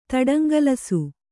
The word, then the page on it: ತಡಂಗಲಿಸು: taḍaŋgalisu